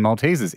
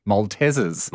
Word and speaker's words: Maltesers: I might be sitting on an absolute gold mine here, but realize there is no A in Maltesers. It's spelt like it should be pronounced Maltesers. – Maltesers. There's no double E either.